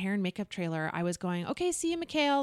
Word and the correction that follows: Michael: Like I was calling him Mikhail. Mm-hmm. Anyways, his name is spelt Michael. – Yeah, yeah. – Like, very, it's like every other person who spells the name Michael, mm-hmm. right? It's M-I-C-H-A-E-L. – Perfect.